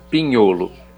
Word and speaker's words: Pignolo: Mudando radicalmente de região de estilo, quem puder provar um Pinholo, da região do Friulo, Pignolo, mas se pronuncia Pinholo, P-I-G-N-O-L-O.